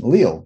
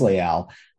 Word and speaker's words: Leal: And, you know, some people pronounce his name Leal, but it is Leal.